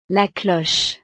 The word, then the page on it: la cloche: frances